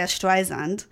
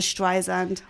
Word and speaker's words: Streisand: I had no idea that you're meant to go Streisand, Barbara Streisand, all my life.